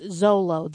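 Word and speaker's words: Xolo: It's so hard to pronounce. I mean, it looks like Mr. McClixifix, whatever it was from Superman comics. – Yeah, but apparently it's pronounced Zolo. The Zolo dog, little dog, I don't know.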